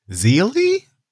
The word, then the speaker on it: Zeely: But over the weekend, Tyrese's new girlfriend, Zeely? – Like Keely, but with a Z?